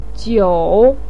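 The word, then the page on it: jiu: tone 3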